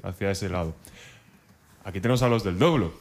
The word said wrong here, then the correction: dobro: ¿Doblo? ¿Dobro? ¿Cómo es eso? ¿Cómo se dice? ¿Qué, qué es un dobro? – Dobro, dobro. Es una guitarra resonadora, una guitarra metálica.